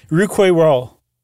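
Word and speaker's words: Rouquayrol: I don't think so, but it looks, and when you pronounce it, it sounds a lot like rock and roll. – Yeah, rook and or roll. – Rouquet Roll. Yeah, rock and roll.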